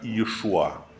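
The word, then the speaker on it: Иешуа (ישוע): Имя Иисус, имя греческое на иврите оно произносится как Иешуа.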